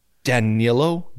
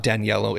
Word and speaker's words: Danilo: So it's Danilo. – Danilo? – Not Daniello. It's Danilo.